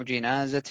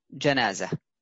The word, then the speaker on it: جنازة: طيب، قال المصنف رحمه الله تعالى: كتاب الجنائز بفتح الجيم جمع جنازة بالكسر. إذن الجمع جنائز بالفتح، والمفرد جنازة بالكسر، وهذا هو الأصل فيها. الأصل فيها أنها تنطق بالكسر، وقد تنطق بالفتح أحيانا، ولأجل هذا قال المصنف: والفتح لغة. يعني أنه جائز لغة، عند لغة من لغات العرب، أن يقال جنازة، ولكن الأصل أن يقال للمفرد جنازة.